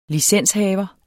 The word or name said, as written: licenshaver